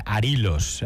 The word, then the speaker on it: arilos: Nos dicen por aquí que es Arilos. Acento en la I. Lleva acento en la I. Arilos. Pues acento lleva que no tilde.